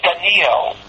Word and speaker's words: Daniel: Also, the name Daniel is pronounced by people as if there's a tzere under the aleph. Daniel, Israel, Ishmael. It's not so.